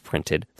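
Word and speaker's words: Print3D: It's called Printed, but instead of an ED, it's a 3D at the end. Print 3D, but it's pronounced Printed.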